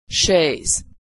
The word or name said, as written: chaise